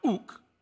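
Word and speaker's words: ouk (οὐκ): And one of them is pronounced ouk.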